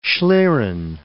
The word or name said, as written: schlieren